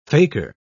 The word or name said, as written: faker